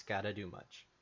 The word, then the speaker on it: Skadegamutc: The vocabulary I have in front of me, the pronunciation is gotta do much.